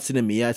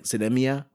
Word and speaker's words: Sinemia: It's not Cinemia. It's supposed to be like Cinemia or something like that.